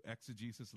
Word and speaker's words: exegesis: It's EXEGESIS. EXEGESIS, exegesis. Eisegesis is EISEGESIS. Let me say it again. EISEGESIS means to lead into. Exegesis, lead out of.